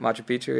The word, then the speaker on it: Machu Picchu: Is that how you pronounce it? – Machu Picchu, yeah.